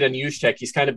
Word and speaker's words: Juszczyk: Also, and I always butcher this dude's last name, Kyle Juszczyk. – Juszczyk. – Thank you.